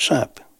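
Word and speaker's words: SAPE: La SAP, pronounced SAP, a French acronym from the Society of Atmosphere, Setters, and Elegant People.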